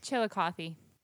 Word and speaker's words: Chillicothe: Chillicothe. – Chillicothe? – Oh, that was such a cute pronunciation. – Chillicloth? – chili cloth. – Chillicothe is how you pronounce that word.